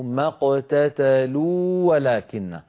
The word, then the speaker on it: ما اقتتلوا ولكن: ما اقتتلوا آخرها واو، ولكن هذه الواو مدية. ولكن أولها واو، فالتقى في النطق واوان. لكننا لا ندغم الواو الأولى في الثانية. لما؟ لأن الواو الأولى مدية. ما اقتتلوا ولكن لا يصح أن ندغم، لأن حرف المد لا يدغم.